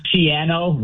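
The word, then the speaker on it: Schiano: By the way, Greg Chiano rhymes with piano.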